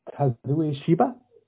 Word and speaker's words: Kazuya Shiba: It was written by a woman named Kazuya Shiba. Did I pronounce that right? – Yeah, pretty much.